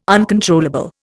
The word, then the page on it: uncontrollable: Indian